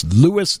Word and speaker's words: Lewes: Now, you might think it would be spelled L-O-U-I-S. – That's what I would think. – But it isn't. Hmm. It's spelled L-E-W-E-S. – Ooh, E-S. – Lewis. It would look like Lewes or Lewes. – Yes. – But it's Lewis, Delaware, which was founded in 1631, named for Lewis in England.